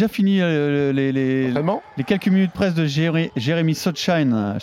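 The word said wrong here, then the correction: Sochan: Sohan, on prononce Sohan.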